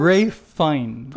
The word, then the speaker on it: Fiennes: Ralph Fiennes. – Fine. Ay, walang S. Walang S pa.